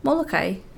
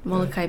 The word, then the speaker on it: Molokai: What does the majority of locals there say? – Molokai. – Molokai. – Molokai. Yeah. Molokai means churning waters. – See, right. I only learned how to pronounce it from the song, and it's Molokai.